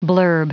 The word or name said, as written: blurb